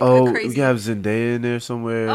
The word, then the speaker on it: Zendaya: Right, Zendaya.